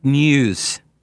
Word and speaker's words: Nieuws: Front News. N-I-E-U-W-S, news. So it's like spelled the same way it sounds.